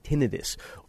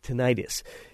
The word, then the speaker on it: tinnitus: That ringing sound is called tinnitus, or can also be pronounced tinnitus, and it's not easily fixed.